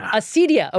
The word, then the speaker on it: acedia: Acedia.